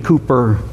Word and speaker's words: Cowper: The poet, songwriter, William Cooper, his name spelled Cowper, pronounced Cooper, said, quote, "The only bliss of paradise that has survived the fall is the home."